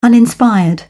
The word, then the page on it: uninspired: British English